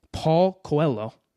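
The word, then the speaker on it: Paulo Coelho: And one of the biggest examples is Paul Coelho the author of The Alchemist. Is yep. that how you say his name? Yeah.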